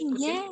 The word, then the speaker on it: Putin: Putin.